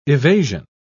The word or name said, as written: evasion